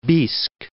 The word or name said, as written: Biysk